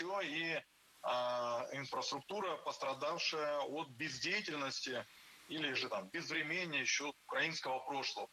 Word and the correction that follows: безвременье: Безвременье, неверно, правильно, безвременье. Ударение в этом слове падает на корень врем. Время, безвременье. Следующий пример попал в наш выпуск все из того же утреннего шоу.